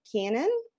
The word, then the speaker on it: Kiernan: I was reading a book called The Murder Box by Olivia Kiernan. That's how you pronounce her last name.